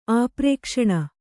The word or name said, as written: ಆಪ್ರೇಕ್ಷಣ